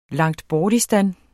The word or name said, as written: Langtbortistan